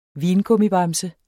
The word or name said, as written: vingummibamse